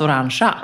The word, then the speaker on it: orange: O-R-A-N-G. Orange. – Ja, fast det uttalas orangea.